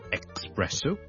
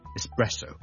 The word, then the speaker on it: espresso: 今天有个朋友叫肖叔叔讲一讲这个话题，就是呢有两个字 ES 字开头的，分别是 ESPECIALLY especially 即特别地呢，尤其是啦 especially 第二个字呢都是 ES 开头的，就是 ESPRESSO espresso espresso espresso 即是意式的特浓咖啡。我们这两个字的 ES 呢，我们都不要将它读成 X，不要 expresso，不要 especially， but espresso e 或者 e 都得，直接加个 S，中间唔好加咗个 K 音，因为 K 音呢我哋 EX 我哋先会有 K 音嘅。